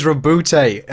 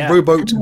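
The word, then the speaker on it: Roboute: It's always Robute, and it's not Roboot Gulliman.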